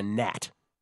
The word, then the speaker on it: gnat: The Nuggets bat them away like they're a gnat. G-N-A-T, Kelly. Silent G.